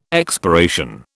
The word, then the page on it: expiration: British English